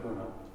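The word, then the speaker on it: Beuno: It looks like Bueno, but I know there's no way that's how you pronounce it. – Buna. – Buna? – Buna.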